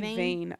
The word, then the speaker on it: Vane: And, he, like, as soon as the sun goes down, and Vane, is that how you say it? – I say Vane. I don't know. – I said Vane as well.